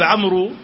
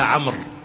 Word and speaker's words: عمرو: تكتب بواو واحدة، كما أن داود تكتب بواوين، تكتب بواو واحدة وتنطق بواوين، فإن هناك واوا عند عمرو تكتب ولا تقرأ، تكتب عمرو ولكن تقرأ عمرو. فواحد شاف داود ما فيها واو، فيها واو واحد، قال له أين واو داود؟